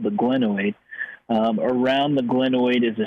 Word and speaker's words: glenoid: He's got a max guaranteed deal, but an MRI performed Monday revealed that Watson had a displaced fracture on the glenoid, his right shoulder. Explain that to us and correct the pronunciation. – Yeah, you bet, Chris. Ah, nice work. Glenoid is perfect.